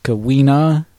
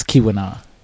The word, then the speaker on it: Keweenaw: I know it looks like it would look like it's pronounced Kawina, but it's Keweenaw.